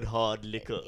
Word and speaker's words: Hrdlicka: Tennis Australia chair Jane Hardlicker, H-R-D-L-I-C-K-A, which is really more of a list of consonants than a name. – That's more of like a Greek Australian pronunciation of the word hard liquor.